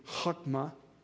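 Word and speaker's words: chokmah (חכמה): The word wisdom, and once again, I put it there for you in Hebrew, and if you pronounce that, that would be chokmah. That H is a hard H, chokmah.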